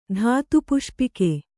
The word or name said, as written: ಧಾತುಪುಷ್ಪಿಕೆ